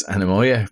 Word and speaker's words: Anemoia: And it's how you pronounce it is Anamoya, but how you spell it, A-N-E-M-O-I-A.